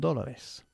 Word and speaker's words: dólar: En dólares. Ok, primero, ¿cómo pronunciamos nosotros dólares? – Dollars. – Dollar, ok. No dollar. Dólar.